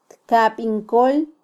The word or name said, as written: tkabin kol